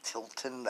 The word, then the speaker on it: Tilton: It's under Daniel Tilton. That's my name, T-I-L-T-O-N. Spell it like that, and you'll say it the same.